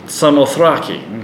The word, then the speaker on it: Samothrace: So from Troas, we put out to sea, verse 11, and sailed straight for Samothrace. Is that how you say it in the Greek? – Samothrake.